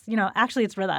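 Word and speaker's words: Ridha: hey, Rita, I'm like, actually it's Rita.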